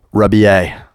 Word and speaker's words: Rebillet: Am I pronouncing that okay? How do you like it? – Rebillet. – Rebillet, Rebillet. Okay, it sounds quite Catalan.